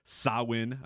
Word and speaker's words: Samhain: I don't know that we can, do the Sawin, even though it's spelled Samhan, it's Sawin.